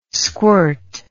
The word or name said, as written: squirt